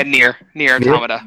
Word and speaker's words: Nier Automata: Yeah, Nier Automata.